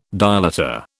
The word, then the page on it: dilator: British English